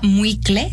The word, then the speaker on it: muicle: Se llama muicle, se escribe M-U-I-C-L-E, muicle.